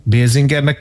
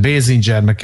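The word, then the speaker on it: Basinger: Kim Basingernek szeretném Basingernek kell mondani, mert Basingernek is mondják, megint bele navigáltuk magunkat a kiejtés cunamiba, szóval én Kim Basingernek köszönöm a Miki Rurkos klippet mm-hmm.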